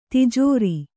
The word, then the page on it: ತಿಜೋರಿ: tijōri